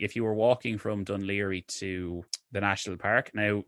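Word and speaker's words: Dún Laoghaire: I'd always tell people it's pronounced Dunleary, you know, or like even like they'd see Port Leash or things like that, and they wouldn't know it.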